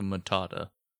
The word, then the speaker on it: Matata: I'm going to do a Hakuna Makata thing, but it's too many syllables and I'm an idiot. It's also Matata. When I say Makata?